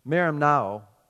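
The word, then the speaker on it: marimnao (μεριμνάω): And that word anxious is pronounced marin, it's hard, marimnao, which means to be troubled with cares or to be pulled apart, to go to pieces.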